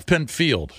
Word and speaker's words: Pinfield: Pinfeld, man, I just went to his... – Pinfield. – A Pinfield. I went to his Wikipedia. I always called him Pinfeld. – That could be wrong. – It's spelled... – It is spelled Field.